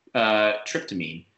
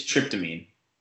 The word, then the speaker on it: tryptamine: Yeah, that's, yeah, it's, I'm going to look it up because I think I know what it is, but I think you absolutely butchered that pronunciation. No offense. – Hey. Happens. So that, it's tryptamine.